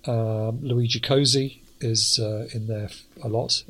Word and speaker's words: Cozzi: Well, I did notice that I've now heard him pronounce his own name, and it is, he does pronounce it Cozzi. So. – Yeah, Cozzi.